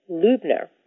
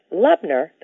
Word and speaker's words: Lubner: Hello, my name is Susan Lubner, and I am very excited to be able to pronounce my name correctly for you because most everybody pronounces my name Lubner. And a great way to remember how to pronounce it Lubner, the correct way, is to notice that the first three letters of my last name, L-U-B, are very similar to T-U-B, Tub.